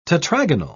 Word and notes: tetragonal: British English

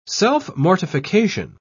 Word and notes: self-mortification: British English

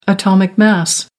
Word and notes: atomic mass: American English

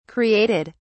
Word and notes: created: British English

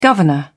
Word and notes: governor: British English

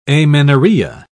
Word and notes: amenorrhea: British English

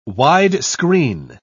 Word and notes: wide-screen: British English

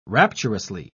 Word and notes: rapturously: British English

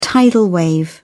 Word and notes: tidal wave: British English

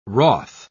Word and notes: wroth: British English

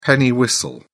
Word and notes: penny whistle: British English

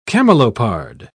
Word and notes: camelopard: British English

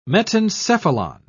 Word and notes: metencephalon: British English

